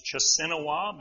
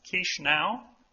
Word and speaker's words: Chișinău: The capital looks like it's Chisinau, but it's pronounced Chișinău.